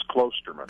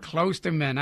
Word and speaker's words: Klosterman: And the last pronunciation was the correct one. It's Klosterman. – Klosterman. I was told that it had a long O, but I am a master at mutilation of last names.